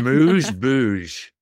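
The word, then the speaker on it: amuse-bouche: A moose boosh.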